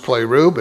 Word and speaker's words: pleroo: It's pronounced pleroo, but it's P-L-E-R-O-O, P-L-E-R-O-O.